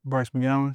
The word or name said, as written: bryce MCGOWENS